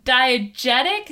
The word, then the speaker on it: diegetic: Most of our music has been diegetic. Is that the word? Am I pronouncing that right? – Yes.